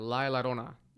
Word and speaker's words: La Llorona: With La Llorona, I have trouble pronouncing that. La Llorona. – Llorona. – Llorona. – Same thing. – Whatever. Like Corona. – Yeah, you're good. Don't worry.